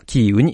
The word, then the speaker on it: キーウ: で、お断りとしましてですね、えー、毎日新聞ではウクライナ首都の表記を、えー、キエフからキーウに変更します。えー、従来はロシア語の表記えー、発音で表記していましたが、ウクライナ語の発音に合わせます。